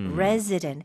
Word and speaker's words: resident: Resident, resident. – 네. – 이거 눈으로 보시면 RES인데요. 발음은 Z처럼 해주시면 좋아요.